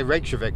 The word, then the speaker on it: Reykjavik: Yes, and I can say Reykjavik because I pronounce the J.